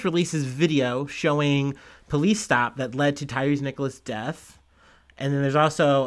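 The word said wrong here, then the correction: Tyre Nichols: Sorry, I'm saying his name wrong. It's Tyree Nichols.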